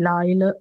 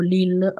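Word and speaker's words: Lyell: Questa reazione allergica si chiama sindrome di Lyle o Lille, a seconda di come si vuole diciamo, pronunciare.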